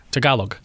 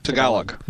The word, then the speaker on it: Tagalog: Just a quick question about the, is it pronounced Tulog? Tulog? – Tagalog. – Tagalog. – Yes.